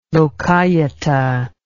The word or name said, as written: lokayata